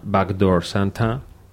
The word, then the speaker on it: Backdoor Santa: Vámonos ahora con Clarence Carter, que sacó un temazo en 1968, que se llama Backdoor Santa.